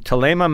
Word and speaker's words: Thelema: I think it's pronounced with a hard T, but it looks like Thelma with an extra E. So from Thelema Mountain Vineyards in Stellenbosch, the 2019 Chardonnay is a stunner, frankly.